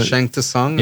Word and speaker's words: Shang Tsung: Did you guys ever used to do that, like, as just for fun, like in the Shang Tsung? – Yeah, because that's how it's pronounced. That's how it's spelled, right? T-S. – Yeah. – U-N-G.